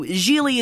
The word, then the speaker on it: Gigli: And so Lainie Kazan comes in as Mrs. Gigli, which also, if you guys don't know, Gigli is pronounced like really because he says that about seven times in the film.